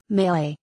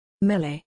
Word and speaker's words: melee: It's not melee, Warren. I'm looking at you. I've also heard it as melee, as in, it's melee. I think if you put the emphasis on the second syllable, melee, I think that's okay. But maybe that's only if you're French.